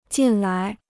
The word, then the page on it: 进来: jìn lái